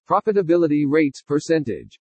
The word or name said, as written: PROFITABILITY RATES-PERCENTAGE